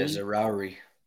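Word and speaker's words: Zaroury: I'm going to butcher this, but Anas Zaharoui? – Yeah, Zaharoui.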